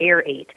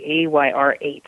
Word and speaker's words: AYR8: A lot of people mispronounce them. They call them the Air 8. They're not. They're actually AYR8s, and they are the most ventilated helmet of any of the Charles Owens models.